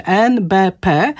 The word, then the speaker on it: NBP: To jest taki skrótowiec, w którym wymawiamy każdą literę osobno, a więc tak jak mówimy alfabet A, B, C, D i tak dalej, no to mówimy NBP.